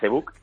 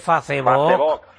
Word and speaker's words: Facebook: A través de ese email lo pueden pedir también, y a través del, como usted le llama, Facebook, @ Reyes Magos Sanlúcar. – No lo ha pronunciado usted bien, es Facebook. Con las dos os, que se note bien.